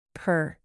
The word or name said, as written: purr